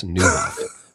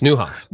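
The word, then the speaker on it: Nieuwhof: It is so simple. It's Nyawoff. It's Newhoff. – Newhoff? – Yep. New, N-I-E-U-W, is the Dutch word for new.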